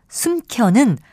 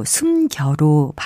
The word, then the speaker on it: 숨겨: 지금 들으신 예문에 나온 혼차 숨켜는 모두 주위의 음성적인 환경을 봐도 거센 소리로 발음될 이유가 전혀 없는 말들입니다. 이것은 글자 그대로 혼자 그리고 숨겨로 발음하는 것이 맞습니다.